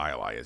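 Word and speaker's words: jai alai: But everybody knows what IOI is. It's very strange that you do not. It's spelled, by the way, J-A-I.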